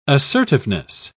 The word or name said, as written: assertiveness